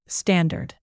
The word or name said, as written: standard